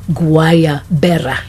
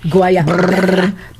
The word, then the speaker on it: Guayabera: So the shirts, she's familiar with the shirts, so here's how you pronounce it. Guayabera. Did you notice the twirling in my pronunciation? – Yeah. You know I'm envious because I can't roll my R's. – Yeah. Guayabera.